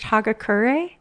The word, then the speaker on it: Hagakure: They're from the, and I'm going to butcher this, I think it's pronounced Hagakure. – Yeah, I believe that's correct.